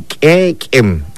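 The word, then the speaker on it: k'éek'en: escúchelo usted bien, quequem, así como se escribe, así se pronuncia, y así queremos que ustedes lo vayan practicando, repito, practicando, todo se aprende.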